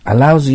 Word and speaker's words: allow: allows you to ってなってますから、 you に to 以下を許すということはさせてくれますよという意味です。発音気をつけてくださいね。 allow ですよ。